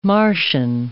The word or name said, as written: Martian